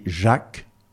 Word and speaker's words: Jack: J-A-C-K. Et j'ai toujours dit que je m'appelais Jacques, comme ça se prononce en français, et jamais à l'américaine ou à l'anglaise, parce que ça ne me convenait pas.